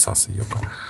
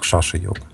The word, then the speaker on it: шаша-йога: То есть индусы, они не могут ше произнести сейчас, они говорят саса-йога. А, хотя буква «С» в санскрите, она читается как шаша. Ше часто. Там есть разные... Я небольшой знаток санскрита, но там в зависимости от положения тоже зависит. Но вот нас учили, что это произносится как шаша-йога.